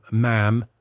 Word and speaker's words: ma'am: And you would say, ma'am. – Ma'am. – Rhymes with jam. – Okay. Not mum, because they say mum.